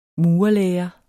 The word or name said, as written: murerlære